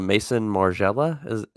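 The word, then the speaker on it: Maison Margiela: And this is a Mason Margiela.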